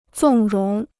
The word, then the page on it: 纵容: zòng róng